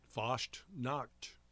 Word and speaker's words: Fasnacht: Fasnacht Day? – Pretty close. Fasnacht.